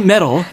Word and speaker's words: meddle: 아, 금속 발음이랑 거의 똑같죠. – 어. metal. 맞아요. – precious metal. 어. – 아, my precious. 아, 왠지 따라가고 싶어지네요. – Don't meddle 네.